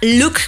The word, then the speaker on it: Looke: Por ejemplo, hay unos, un servicio que se llama Look, bueno, se escribe L-O-O-K-E, que es la primera plataforma brasileña de streaming en operar en México.